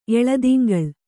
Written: ಎಳೆದಿಂಗಳು

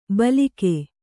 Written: ಭೀಲುಕ

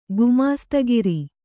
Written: ಗುಮಾಸ್ತಗಿರಿ

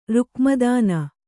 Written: ರುಕ್ಮದಾನ

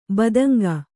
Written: ಬೆದಂಕು

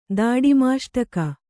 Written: ದಾಡಿಮಾಷ್ಟಕ

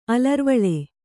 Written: ಅಲರ್ವಳೆ

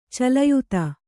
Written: ಚಲಯುತ

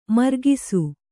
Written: ಮರಗಸ

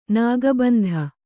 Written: ನಾಗಬಂಧ

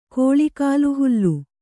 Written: ಕೋಳಿಕಾಲುಹುಲ್ಲು

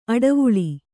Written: ಅಡುವಳ್ಳ

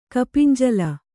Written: ಕಪಿಂಜಲ

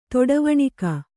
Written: ತೊಡವಣಿಕ

